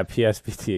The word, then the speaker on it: PSBT: Yeah, I mean, with Cold Card, when you use like the PBST option, it PSBT? – Yeah, PSBT, exactly. I knew I said that wrong.